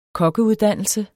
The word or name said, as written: kokkeuddannelse